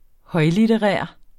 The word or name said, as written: højlitterær